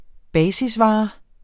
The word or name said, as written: basisvare